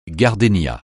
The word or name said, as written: gardénia